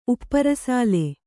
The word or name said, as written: ಉಪ್ಪರಸಾಲೆ